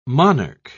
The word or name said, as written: monarch